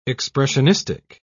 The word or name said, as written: expressionistic